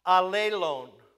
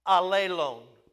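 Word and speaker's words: allelon (ἀλλήλων): The one word in the Greek is pronounced allelon, allelon.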